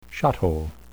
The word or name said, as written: shot-hole